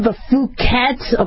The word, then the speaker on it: Phuket: but it's the Phuket. But it's supposed to be a short U and not a long U, the Phuket, okay?